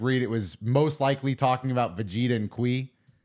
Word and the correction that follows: Kiwi: Kiwi, by the way. – It's pronounced Kiwi? – His name in the manga is Kiwi.